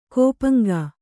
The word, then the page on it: ಕೋಪಂಗಾ: kannada